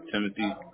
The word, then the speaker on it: Timothy: Timothy.